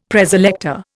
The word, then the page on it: Preselector: Indian